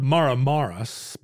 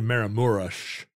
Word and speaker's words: Maramureș: So it looks like it's spelled Maramaras, but it's actually Maramureș.